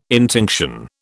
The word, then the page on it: intinction: British English